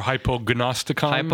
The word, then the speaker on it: Hypognosticon: Am I saying that correctly? Hypognosticon? Hypo, did I say it? Yeah. Or hypognosticon? Hypognosticon. Yeah, go for it.